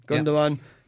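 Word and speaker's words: Gündoğan: Gundogan.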